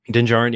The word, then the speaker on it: Din Djarin: Din Djarin, yeah, the D is silent. No double D's.